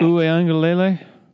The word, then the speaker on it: Uiagalelei: DJ Uiagalelei. I've been working on that. – Uiagalelei? – Correct.